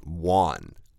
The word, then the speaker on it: Juan: Right, so when we see Spanish names, especially Mexican Spanish names, the J is not pronounced J, it's pronounced, like for example, J-U-A-N is pronounced Juan.